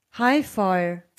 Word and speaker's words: High Fire: also H-I-G-H und F-I-R. Mhm. High Fire oder High 4? 4? Wie wird das ausgesprochen? – High 4, nicht Viere? Nee, ohne E hinten. High Fire.